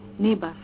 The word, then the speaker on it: NIBAS: In your view, what are the main bottlenecks it's called NIBAS. Is that how you pronounce it? NIBAS, yes, program.